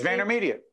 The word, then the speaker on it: VaynerMedia: Yes, VaynerMedia.